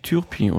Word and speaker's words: Turpin: Na, er ist eigentlich Turpin oder so. Turpin? Turpin. Es ging, ja, glaube ich, dass das genau. U als Ü ausgesprochen genau. wird. Genau, ja. es war kein OU.